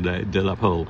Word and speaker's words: de la Pole: But, the French pronunciation, De La Pole.